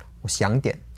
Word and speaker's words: 我想点: So that's like half a third tone, a second tone, and then a third tone again. 我想点.